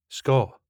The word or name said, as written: Scot